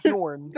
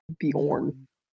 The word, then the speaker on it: Bjorn: Like spelled B-J-O-R-N? – Sure. Sure. – Is that how it's spelled? – Yes. – Yeah, Bjorn. – Bjornsson. – Not Bjorn, it's Bjorn. – My really said Bjornsson. – In those type of languages, J's are usually like Bjorn.